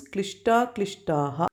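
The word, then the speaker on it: kliṣṭākliṣṭāḥ: Klishta Aklishtaha together becomes Klishta Klishtaha.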